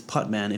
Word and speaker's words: Putman: Yes, it's Putman. If you spell it Putnam, you're like, I can't find you.